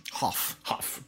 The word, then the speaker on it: Hough: I'm speaking of John Howe's The Legend of Hell House. – All right. Another one I haven't seen. – Might be John Huff. I'm actually not sure I pronounce his name. H-O-U-G-H. – Huff.